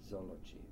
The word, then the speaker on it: Złoczów: But I was born in July 1937 in a town called Złoczów. It's pronounced in Polish. Now Złoczów.